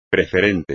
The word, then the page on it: preferente: espagnol